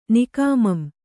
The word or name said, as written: ನಿಕಾಮಂ